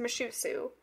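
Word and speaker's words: Mushussu: From my understanding of how to say this, it's pronounced Mushusu.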